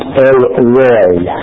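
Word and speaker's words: El Roi: Let's move on to the last one, which will be a lot quicker, the name El Roy. pronounced El Roy.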